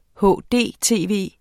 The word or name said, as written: hdtv